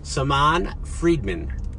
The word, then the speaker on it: Saman Freedman: We're back on the Captain's Log with Dr. Saman Friedman.